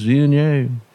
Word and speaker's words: Viognier: Condrieu is an appellation in northern Rhone, if you're not familiar with it, and the grape is Viognier. – Viognier. – The author for footnote B. – Exactly. And sometimes pronounced Viognier. – Viognier. Mm-hmm. – Voyner. – You know. Oh, Voyner is always a good one, yes.